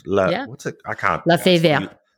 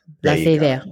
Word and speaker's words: La Fée Verte: La Fée Verte. La Fée Verte.